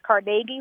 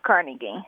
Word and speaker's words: Carnegie: Carnegie, accent on the second syllable. – With more of an A than an E. – Right. – So the Carnegie Hall that exists in New York should actually be pronounced Carnegie Hall?